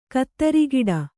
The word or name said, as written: ಖುದ್ರಗೇಡಿ